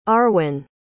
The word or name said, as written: Arwin